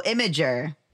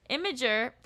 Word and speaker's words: Imgur: I always in my head just went Imgur. – No, it's like because people put pictures on it. That's the whole point, right? – Imgur.